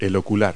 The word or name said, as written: eyecup